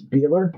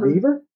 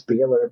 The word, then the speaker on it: Behler: Well, you know, you and I would say my name's Beeler, not Beaver.